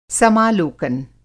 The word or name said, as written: समालोकन